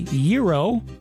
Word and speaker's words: gyro: How do you say the word spelled G-Y-R-O? Some people say gyro, others say gyro.